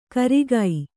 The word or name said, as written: ಕರಿಗಿ